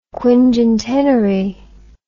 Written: quingentenary

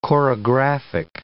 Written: chorographic

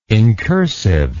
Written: incursive

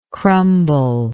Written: crumblingness

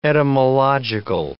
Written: etymological

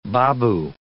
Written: baboo

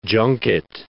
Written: junket

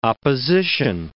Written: opposition

